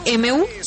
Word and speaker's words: Mu: ¿Así se pronuncia, aunque se escribe M-U? – Sí, señora, perfecto. Se sabe el alfabeto griego. Se pronuncia Mu y el nombre ahora será M-U, según lo que dice también la Organización Mundial de la Salud y el Instituto Nacional de Salud.